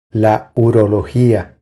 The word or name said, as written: La urologia